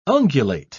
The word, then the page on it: ungulate: British English